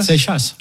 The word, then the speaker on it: Seixas: Oui, il y a le X, mais il n'y a pas la prononciation. – C'est le futur vainqueur du Tour de France. – C'est comme Louis Flip. – Ah, c'est que ça ? – Seixas.